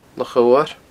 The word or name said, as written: Loch Odhar